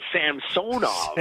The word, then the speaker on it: Samsonov: So that the fans aren't getting on me when I'm saying Samsonov and they're telling me, no, it's Samsonov. And it's like, no, I realize in the past it's been Samsonov, but here's Ilya on tape 3 weeks ago, you know, before training camp, telling us how to say his name.